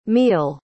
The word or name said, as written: mehul